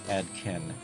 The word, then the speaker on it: Adkehn: It's spelled A-D-K-E-H-N. Adkin. Square, oh no, Scare in a Box with Coming Up Next.